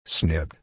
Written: snipped

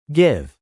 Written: give

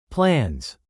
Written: plans

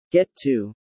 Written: get to

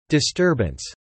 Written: disturbance

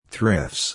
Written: thrifts

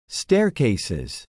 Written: staircases

